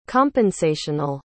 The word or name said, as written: compensational